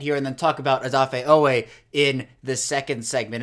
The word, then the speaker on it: Odafe: and that is Odafe, spelled O-D-A-F-E, but he says now he doesn't care, and people are going to have to learn it, so let's learn it.